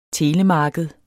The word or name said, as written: telemarked